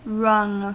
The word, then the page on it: wrung: American English; /ˈrʌŋ/